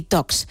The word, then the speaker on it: Talks: Son charlas de tecnología, por eso Tech y Talks. Es que no se pronuncia la L, queremos ir de casi nativos, ¿no? Hay que pronunciarlo bien.